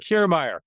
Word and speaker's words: Kiermaier: Kiermaier.